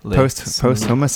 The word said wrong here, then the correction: posthumous: Have I said it wrong? Is it posthumous? I think, isn't it? – Posthumous. Oh, yeah.